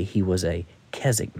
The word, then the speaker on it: Keswick: Most Americans pronounce that Keswick, but the W is silent. Keswick.